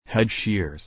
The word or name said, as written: hedge shears